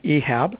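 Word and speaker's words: Ihab: And, I'll start off first by pronouncing my first name the right way in case some of the callers want to ask about that. So my first name is actually Ehab, and it's spelled with an I, but I get that question quite a bit about the correct pronunciation of it.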